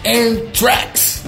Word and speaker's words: Anthrax: Estou falando do Anthrax, ou para quem gosta, né? Anthrax, né? que é a pronúncia é correta.